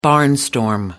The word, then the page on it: barnstorm: American English